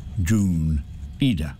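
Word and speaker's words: Jun Iida: I say boy because he was 19 when he did this, and his name is Yoon Ida, spelled J-U-N, and his surname I-I-D-A, pronounced Yoon Ida.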